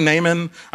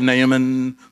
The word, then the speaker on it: Naaman: I've heard his name pronounced differently. So it's most commonly Naaman. I'm trying to give it a little bit of Naaman flair.